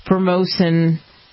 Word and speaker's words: Formosan: a single colony of Formosan termites, that's how you say it,